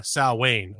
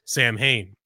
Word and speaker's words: Samhain: It actually first came about around 2,000 years ago as a pagan holiday called Samhain, or more commonly mispronounced, Samhain.